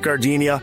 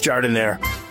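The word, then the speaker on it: giardiniera: And guys, when you're at the counter, don't call it Gardenia. It's pronounced Jardinere.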